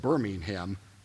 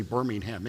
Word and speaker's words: Birmingham: It was going to be in the very, very heavily urban area of, I say it, Birmingham, because I can't bring myself to say Birmingham, England, because it's Birmingham is the British city. That's how they pronounce it, but it's Birmingham, England, which is the second largest metropolitan area next to London.